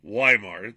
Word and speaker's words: Weimar: Look up the Weimar, I'm going to say it wrong so you can spell it right, the Weimar Republic, Weimar Republic. It's really the pronounced Weimar. They pronounce, the Austrians pronounce VW and Germans alike.